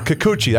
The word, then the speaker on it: Kikuchi: Is it Kikala or? – Yeah. Yeah, from Seattle. – Wow, is he good. – Yeah. – He is really good. – Yeah, he pitched here, right? – Yes, he did. Yeah, I liked him a lot. Yeah. – Or Kikuchi. I think that's how he pronounced his name.